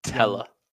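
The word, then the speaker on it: Tella: Ethan Ampadu took out Nathan Teller. I don't know how you pronounce his name. – Teller, young Saints player.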